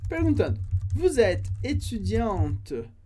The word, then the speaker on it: étudiante: No caso do feminino estudante ou aluna, a gente tem a letra E, que não é pronunciada no final, porque a gente viu que o E no final a gente corta. Mas, com o E, eu pronuncio a letra T, que vem antes. Étudiante, étudiante. Então, masculino, étudiant, étudiant, e feminino, étudiante, com esse T no final.